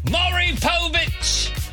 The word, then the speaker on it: Maury Povich: Maury Povich!